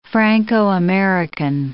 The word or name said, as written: Franco-American